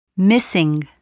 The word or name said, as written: missing